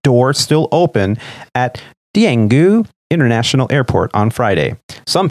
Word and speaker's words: Daegu: The name of the airport is Daegu. – Oh, it's the D is pronounced like a T. – It's yeah. Daegu. It's a soft T. – It's a soft Daegu.